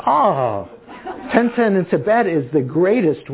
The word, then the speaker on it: Tintin: Is that pronounced right? Tintin? Yeah.